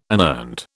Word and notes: unearned: British English

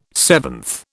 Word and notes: seventh: British English

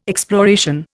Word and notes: exploration: Indian

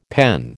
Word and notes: PEN: American English

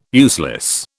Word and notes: useless: British English

